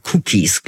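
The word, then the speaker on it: Kukies: Und der Mann heißt Jörg Cookies, glaube ich, so spricht man ihn aus.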